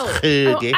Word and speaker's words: hygge: It's spelled H-Y-G-E-E. It's pronounced Hoogie. – Oh, I think it's H-Y-G-G-E. – It's spelled H-Y-G-G-E.